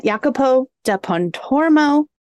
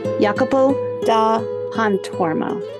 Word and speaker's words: Jacopo da Pontormo: Jacopo da Pontormo, who I have characterized as painfully shy, putti perfectionist, and Albrecht Dürer stan. Jacopo da Pontormo.